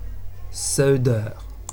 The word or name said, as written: seuder